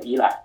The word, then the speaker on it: Eli: 也很简单，因为我的英文名拼写呢叫 E L I，念的话呢就念作依赖。